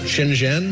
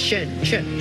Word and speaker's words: Shenzhen: Shenzhen. – No, it's Shenzhen.